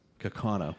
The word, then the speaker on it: Kaukauna: They're off of County Road UU in Kaukauna, Wisconsin, I think. Is that how it's pronounced, guys? – Yes. – Kaukauna, Wisconsin.